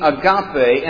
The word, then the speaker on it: agape: Now, if we were to spell it in English, it would look like agape, A-G-A-P-E. But it's pronounced agape. Here's a good way to remember it, though, because when you see it, you'll do this. And your mouth will be agape, because that's what agape looks like.